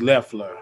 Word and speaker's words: Loeffler: It's Leffler. I was saying it's Kelly Leffler, not Kelly Loeffler.